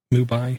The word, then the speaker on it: Mumbai: Okay, this is a Mubai, I can't even say it right, Mubai-based company. Mumbai, I think it's how it's pronounced.